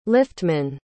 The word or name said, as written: liftman